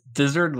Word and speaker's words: Dizzard: It's Dizzard like lizard.